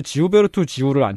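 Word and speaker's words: Gilberto Gil: 문화, 문화부 장관의 지우베르토 지우를 앉혔어요. 한국에는 질베르토 질이라는 이름으로 알려져 있는 보사노바 가수입니다. 같은 이름입니다. 네. – 발음이 다를 뿐.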